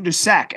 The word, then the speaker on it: Prairie du Sac: Maybe I say this wrong, but Prairie Du Sac. – Prairie Duchesne. – Duchesne? Du Sac, SAC.